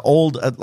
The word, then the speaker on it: Auld: Yeah, the old, like A-U-L-D, like it's pronounced old.